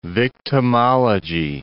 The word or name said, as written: victimology